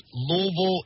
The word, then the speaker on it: Louisville: Y'all aren't saying Louisville or Louisville. It's Louisville, and I'm so happy and so thankful that y'all have said it correctly. Louisville.